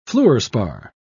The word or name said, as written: fluorspar